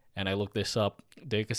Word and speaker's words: Deika: Is it Dekai? It's spelled D-E-I-K-A. – Oh, I wrote it wrong then. – Yeah, I forget how they pronounce it Deka? In the show. Yeah, D-E-I-K-A, Deka.